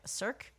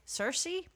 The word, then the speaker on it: Circe: Madeline Miller is the author's name, and I really loved her book Cirque Circe.